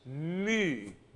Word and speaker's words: mu: It's the letter for M, mm, but it's mu, mu. sort of looks like a U with a tail. And this is the equivalent of U, mu, U, like the U umlaut.